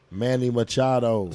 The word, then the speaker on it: Manny Machado: Manny Machado.